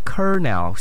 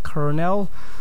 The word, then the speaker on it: colonel: One more time, kernel. C-O-L-O-N-E-L. Kernel.